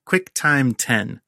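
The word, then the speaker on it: QuickTime X: The first we'll talk about is QuickTime 10, pronounced 10, stylized X.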